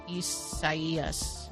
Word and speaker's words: Isaias: Well, no, but it's probably the Spanish rendering of it, which if that's the case, then the I is pronounced like an E. – Isaias.